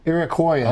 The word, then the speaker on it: Iroquoian: What does the word Canada mean in Iraqian? – I wouldn't. – Is it like hello? – Is it Iraqian? How do you say it? – Iroquoian.